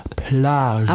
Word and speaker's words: Plage: Ich habe es sehr französisch, ja, ausgesprochen. Plage.